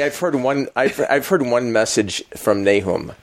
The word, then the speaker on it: Nahum: How do you say it? Nahum? Nahum. Nahum. I speak English. We say Nahum. Okay, Nahum. – In that book. – It is Nahum. It is probably Nahum, because I have to look at the Hebrew and see what the vowel pointing is on that.